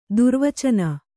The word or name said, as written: ದುರ್ವಚನ